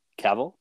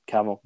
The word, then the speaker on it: Cavill: saying Cavill is pronounced like travel. So Cavill, travel.